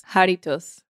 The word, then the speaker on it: Jarritos: Jarritos. – Jarritos.